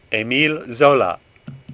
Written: Zola

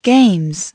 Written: games

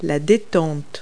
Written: lever

